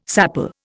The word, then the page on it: sapele: Indian